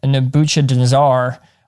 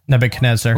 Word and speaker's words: Nebuchadnezzar: In 587 BCE, Babylonian Nebuchadnezzar army... – Nebuchadnezzar.